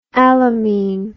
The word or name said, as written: alamine